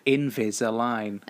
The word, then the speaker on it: Invisalign: So I've got, how do I say it, Invisalign? – Invisalign.